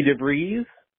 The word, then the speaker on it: DeVries: I was there with Ludacris Laurie and fabulous Vicky DeVries, rhymes with cheese.